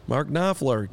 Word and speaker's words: Knopfler: Mark Knopfler.